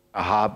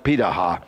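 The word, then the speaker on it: Pirahã: It's spelled like this, and it sounds like it ought to be Pitahá, but they pronounce it Pitahá.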